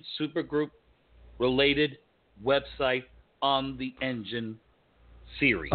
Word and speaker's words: Enjin: Engine. And it's spelled ENJIN. Okay.